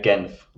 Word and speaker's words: Genf: Depends on what language you're trying to pronounce it in. So in English, it's Geneva. French, it's Genève. Mm. And we German, because I'm in the German-speaking part in near Zurich. – Yeah. – And we just say Genf.